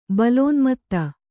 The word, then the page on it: ಬಲೋನ್ಮತ್ತ: balōnmatta